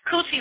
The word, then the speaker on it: Khuti: Kuti, let me put that in the chat room. Okay, because it's spelled K-H-U-T-I. At least that's the way Dr. Suzar is spelling it. And that sounds very similar to cunt or cunty, if you ask me, and you all know that's God right there.